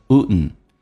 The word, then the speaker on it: wouldn't: wouldn't，当 D 跟 N 在一起，通常 D 可以不发音，气流就阻塞一下变成 wouldn't。